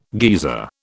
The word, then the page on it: geezer: British English